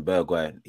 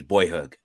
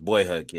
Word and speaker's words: Bergwijn: Yeah, you man are calling him Bergwijn. His name's Boyhug. Yeah. – That's crazy report. – Oh, his name was Boyhug.